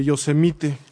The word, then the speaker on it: Yosemite: En el número 40 de la calle Yosemite, o Yosemite, ¿cómo se pronuncia? Tú que vives pues, por ahí. Eh, Yosemite.